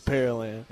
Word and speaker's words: Pearland: Some people always wonder, is it Pearland or Pearland? And you tell them how to say it correctly. – It's Pearland.